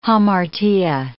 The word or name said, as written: hamartia